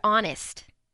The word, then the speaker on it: honest: It is a silent letter just like the H in the word honest.